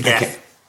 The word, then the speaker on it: Geth: So I'm surprised they didn't mention the Gelf at all at any point in this one. Or the Gelf, or am I pronouncing that right? I forget already. – The Geth.